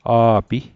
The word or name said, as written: a'pi